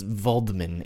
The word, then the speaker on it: Waldman: I meant Dr. Wallman. – I believe it's pronounced Waldman in German.